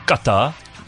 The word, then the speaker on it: Qatar: It's pronounced Qatar.